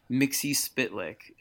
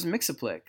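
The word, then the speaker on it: Mxyzptlk: So James Tynan is adamant, like fight you adamant, that you're supposed to pronounce it Mixie Spitlick. And I had never heard that. – Really? That's interesting. – I always thought it was Mixaplick.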